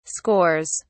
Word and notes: scores: British English

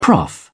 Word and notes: prof: British English

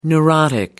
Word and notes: neurotic: American English

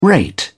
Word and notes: rate: British English

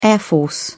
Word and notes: air force: British English

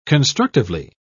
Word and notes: constructively: British English

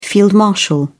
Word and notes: field marshal: British English

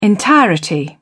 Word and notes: entirety: British English

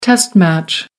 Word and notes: test match: American English

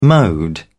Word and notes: mode: British English

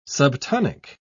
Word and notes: subtonic: British English

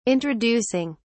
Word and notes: introducing: British English

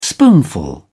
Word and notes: spoonful: British English

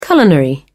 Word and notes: culinary: British English

